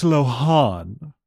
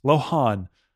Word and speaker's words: Lohan: I don't think I had dreamed it though, that is how you're supposed to say it. That's how her name is pronounced. Lohan.